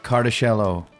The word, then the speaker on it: Cartusciello: Welcome to the Hang, Thalia Ranjbar, Cat Cartusciello.